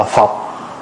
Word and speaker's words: phược: Cho nên nói hoặc do bốn đại mà nổi phiền não, rồi hoặc do bốn phược mà nổi phiền não. Chữ phược á, có khi đọc là phọc, ở ngoài Bắc người ta đọc là phọc.